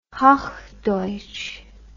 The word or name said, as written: hochdeutsch